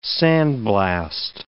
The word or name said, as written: sandblast